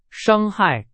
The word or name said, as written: 伤害